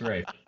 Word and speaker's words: Ralph: Could it have been Rafe Fiennes? Because it's spelled the same, but it's pronounced Rafe.